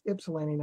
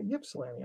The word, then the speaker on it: Ypsilanti: He goes, that wasn't too bad, except you just mispronounced Ypsilanti. It's Ypsilanti, not Ypsilanti.